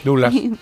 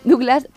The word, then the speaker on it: Douglas: Lo habría hecho Michael Douglas o Douglas, nunca sé cómo se dice, el hijo. – Douglas. – Claro, Douglas, pero...